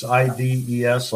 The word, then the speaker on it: Ives: It's IDES, like St. Ives, or I guess people are too.